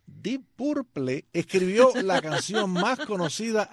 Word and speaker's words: Deep Purple: Deep Purple.